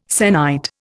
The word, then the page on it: sennight: Indian